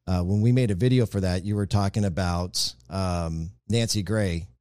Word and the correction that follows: Grace: Did I say that right? Nancy Grace? It's Grace.